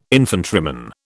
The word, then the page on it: infantryman: British English